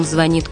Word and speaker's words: звонит: Не спрашивай, по ком звонит колокол. Он звонит и по тебе. Здесь интересно не только ударение, о нем мы уже сказали. Оно такое же, как и в других звенящих предметах.